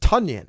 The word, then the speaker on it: Tonyan: The backstory is that he was Robert Tonyan, Bob Tonyan, and then one day he said, "No." After like 3 years of everybody calling him it, he said, "No, it's pronounced Tonyan."